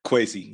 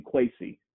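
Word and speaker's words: Kwesi: The other that has me interested is, and I hope I don't butcher his name, Kwaise Adolfo Mensah. – Kwaise, yes. – Thank you, Kwaise.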